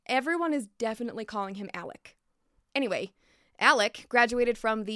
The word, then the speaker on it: Alec: He goes by Alec, which is spelt like Alex, because it's a nickname from his middle name, Alexander.